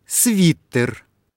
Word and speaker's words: свитер: Свитер или свитер? – Свитер.